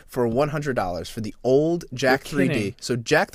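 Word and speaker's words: Jack3d: Jack 3D is it's spelled Jack like the name Jack J A C K 3D, so jacked.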